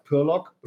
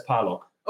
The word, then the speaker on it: Parlog: So my last name is actually Romanian. Right. So like there's no predefined way to say it correctly. Like Romanian way is more like Parlog. Nobody in Germany says that. Even my family doesn't say okay. – that. Okay. So my family says Parlog.